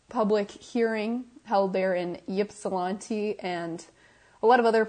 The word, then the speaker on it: Ypsilanti: It's Ypsilanti, Michigan. Am I pronouncing that correctly? Ypsilanti, Michigan. I believe you are correct.